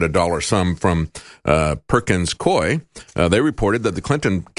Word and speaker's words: Coie: They say it was Washington lawyer Mark Elias and his law firm, Perkins Coie, I think is the way you pronounce that, C-O-I-E.